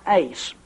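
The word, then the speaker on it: ace (εἰς): The word "unto" is translated from a little Greek preposition and pronounced "ace," and the significance of "ace" is that it is involved here or included in a purpose clause.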